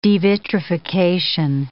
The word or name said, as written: devitrification